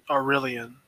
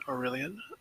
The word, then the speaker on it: Aurelian: It's in Aurelion. It's spelled A-U-R-E-L-I-A-N, Aurelion. Aurelion,